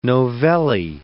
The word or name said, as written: novelle